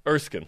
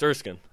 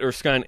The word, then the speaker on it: Erskine: Erskine. That's how you say it. – Is it Erskine or Erskine? – It's Erskine. – Oh, yeah. So you're okay. – I've been saying it Erskine incorrectly. It's Erskine.